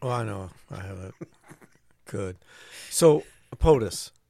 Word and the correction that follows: poultice: what's that used for? – A poultice? – Poultice. – A poultice? – Yes, thank you for letting me pronounce that correctly.